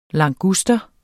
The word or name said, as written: languster